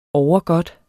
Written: overgodt

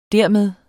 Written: dermed

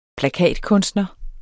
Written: plakatkunstner

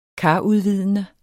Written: karudvidende

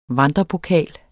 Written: vandrepokal

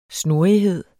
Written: snurrighed

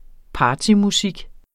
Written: partymusik

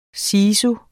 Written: sisu

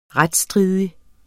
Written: retsstridig

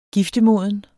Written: giftemoden

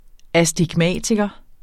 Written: astigmatiker

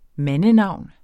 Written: mandenavn

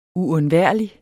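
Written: UUNDVÆRLIGT

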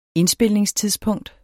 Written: indspilningstidspunkt

